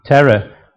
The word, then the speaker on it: Terah: From Shem's line came this man, Terah, who we're going to look at tonight. I think that's how they pronounce it. T-E-R-A-H. So that's the Yorkshire way of pronouncing it anyway.